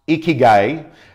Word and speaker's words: ikigai: It's pronounced ikigai and it's Japanese.